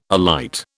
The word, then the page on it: alight: British English